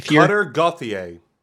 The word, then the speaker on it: Gauthier: Gauthier? – Cutter Gauthier.